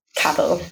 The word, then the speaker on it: Cavill: Cavill? Cavill? – Yeah. – Cavill? – Cavill.